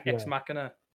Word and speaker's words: Ex Machina: It's pretty good, but it's not as good as Ex Machina, in my opinion. – Ex Machina, is that how you say it? – Yeah, Ex Machina.